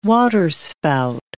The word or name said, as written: waterspout